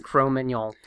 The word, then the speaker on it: Cro-Magnon: I did talk to this researcher. It's Cro-Magnon. – Okay. So anyway, listeners, you can say it right, or you cannot sound like an asshole.